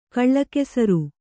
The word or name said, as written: ಕಲಾಕುಸುರಿ